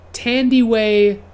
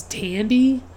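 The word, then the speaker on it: Thandiwe: And I always, like, was confused by her name because I had to look up the pronunciation on Wikipedia. It's Tandiwe. And it's like, I had always seen it, like, misspelled without the W, and I just thought it was Tandy. But, like, it's Tandiway Newton.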